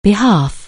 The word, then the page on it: behalf: anglais